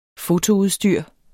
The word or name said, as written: fotoudstyr